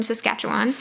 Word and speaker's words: Saskatchewan: Mr. Beekeeper from Saskatchewan.